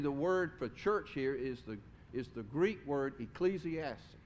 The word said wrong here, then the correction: ekklesia: It's ekklesia. I said that wrong. Yes, ekklesia. And ecclesia always refers to a gathering of people united by a common identity and a purpose.